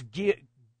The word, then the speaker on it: Guillaume: I mispronounced his name. It's not Guillermo. It's Guillaume.